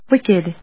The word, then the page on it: wicked: wick・ed; wíkɪd